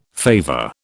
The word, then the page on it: favour: British English